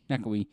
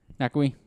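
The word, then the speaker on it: Ngakoue: Nakui. – That's how I pronounce it. – Nakui, that's how you would say it? – Yeah.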